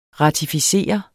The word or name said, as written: RATIFICERE